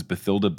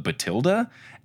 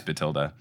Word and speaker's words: Bathilda: Fun fact about the audiobooks, Stephen Fry pronounces Bathilda Batilda. And is that the real pronunciation or is that a British thing? I keep thinking it was Bathilda. – Well, Jim Dale also pronounces it as Batilda. – Batilda. – Okay. – I always have read it to rhyme with Matilda, which is also spelled with an H. – Oh, Matilda's with an H? Okay. Mm-hmm. Then it probably is Batilda.